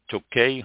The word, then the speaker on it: Tokaj: K-T-O-K-A-J. I don't think the J is pronounced. Tokaj, Hungary. And this, 1,000-year-old wine region is noted for its distinctive Azu, A-S-Z-U, wine.